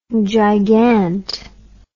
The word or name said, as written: gigant